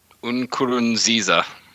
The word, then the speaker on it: Nkurunziza: Nkurunziza.